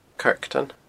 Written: Kirkton

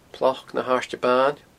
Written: Ploc na h-Airde Baine

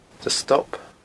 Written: Stob

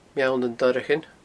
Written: Meall nan Doireachain

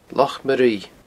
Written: Loch Maruibhe

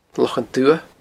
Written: Lochan Dubha

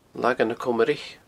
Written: Lagan na Comraich

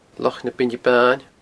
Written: Loch na Beinne Baine

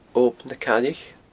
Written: Ob na Caillich

